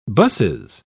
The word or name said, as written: busses